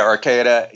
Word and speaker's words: Arcata: Arcata.